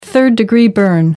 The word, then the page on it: third degree burn: American English